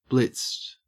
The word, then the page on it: blitzed: Australian English